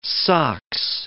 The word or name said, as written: sox